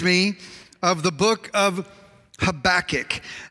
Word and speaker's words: Habakkuk: If you want to, when I hear it, I think maybe like when a cat is like hacking up a hairball. Habakkuk. So everybody say Habakkuk one more time. Habakkuk.